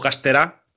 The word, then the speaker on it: Castera: David Castera, o Castera, creo que se pronuncia,